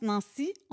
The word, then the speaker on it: Nancie: Puis pour que les gens prononcent Nancy, on va écrire N-A-N-C-I-E. – Ah. – Pour pas qu'ils mélangent avec Nancy. Ça n'a pas marché. Ça fait 56 ans que je me bats avec mon prénom.